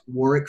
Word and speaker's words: Warwick: You can also find Warwick on LinkedIn at Warwick Fairfax. That's with the silent W in the middle, W-A-R-W-I-C-K, Fairfax.